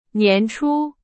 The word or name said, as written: 年初